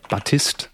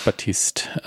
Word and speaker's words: Baptiste: Nächste, Sharon Battiste oder Batist. Weiß nicht, ob das eh hinten mhm. stumm bleibt oder ausgesprochen wird. Vermutlich Baptiste. Ähm, Baptiste.